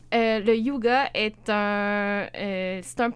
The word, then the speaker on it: hygge: Ça s'appelle le yoga. – OK. – Et ça s'appelle H-Y-G-G-E. – Et non yoga. – Non, c'est ça, mais j'ai vraiment cherché la prononciation pour savoir yuga. Comment le dire.